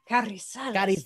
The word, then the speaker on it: Carrizales: See, I was going to say, I liked your pronunciation of my last name. You said Carrizales.